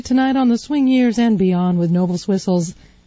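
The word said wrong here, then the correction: Noble Sissle: I didn't say that right. Noble Sissels Swingsters.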